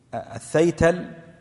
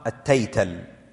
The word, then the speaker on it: الثيتل: والثيتل، الثيتل بالثاء المثلثة في أوله، وبعضهم ضبطها التيتل بالمثنات، التيتل.